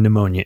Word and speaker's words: pneumonia: P in pneumonia is silent for those of you illiterates out there.